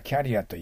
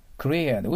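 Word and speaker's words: career: これは日本語ではキャリアと言います。違います。キュリアです。だから、クリアだからカリアって聞こえます。そこに書いておいたけど。クリアで後ろにアクセントがあります。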